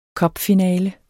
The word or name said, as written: cupfinale